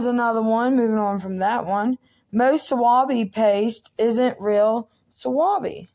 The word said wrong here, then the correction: wasabi: I think I pronounced that wrong. – Well, you know what? Close enough. It's wasabi.